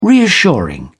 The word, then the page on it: reassuring: British English